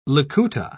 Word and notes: likuta: British English

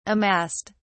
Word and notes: amassed: British English

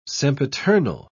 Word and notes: sempiternal: British English

